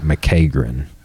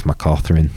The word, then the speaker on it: McCaughrin: So that Brandon McCatherine, when he was on, his name spelled like McCagrin. – Oh. – But Brandon, he's like, it's McCaughrin.